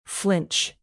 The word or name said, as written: flinch